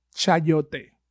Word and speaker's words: chayote: Solid so if you want to find out what a what this fruit looks like, it's spelled C H A mm-hmm. Y O T E, chayote.